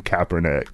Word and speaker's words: Kaepernick: I think we just traded Drake for Colin Kaepernick.